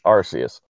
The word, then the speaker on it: Arceus: It's Arceus.